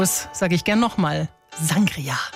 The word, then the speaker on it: Sangria: Das Getränk steht da drin, heißt Sangria, also Betonung auf dem I.